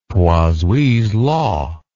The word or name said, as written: Poiseuille's law